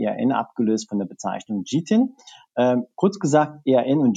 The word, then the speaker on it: GTIN: Ähm, aber offiziell ähm, nennen wir sie mittlerweile ähm, GTIN kurz gesagt oder Global Trade Item Number, also GTIN ausgesprochen.